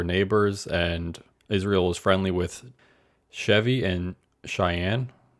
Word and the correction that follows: Cheyne: No, Cheyne. C H E Y N E. Cheyne.